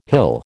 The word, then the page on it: hill: American English